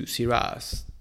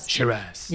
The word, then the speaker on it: Shiraz: The toffee taste is not only related to Syrahs, Shiraz or Syrah. How do you say in Australia? – Shiraz. Yeah. – Or Syrah in the French side.